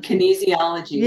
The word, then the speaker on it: kinesiology: Well, I ended up going to a chiropractor, but she does what you talked about and what you do of the applied kinesiology. Is that, did I say it right? – Yeah, it's energy in motion, kinesiology.